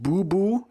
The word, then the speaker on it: Boeboe: Yeah, so my music pick is it's a track called Drift by a producer from Holland, who is pronounced Boo Boo, but it's written B-O-E-B-O-E.